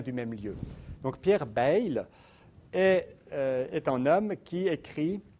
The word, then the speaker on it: Bayle: Bayle, philosophe français, et on prononce son nom maintenant de cette façon-là. Quand j'étais étudiant, j'entendais toujours parler de Pierre Bayle. On prononce plutôt Bayle, puisqu'il vient du Béarn, exactement comme François Bayrou.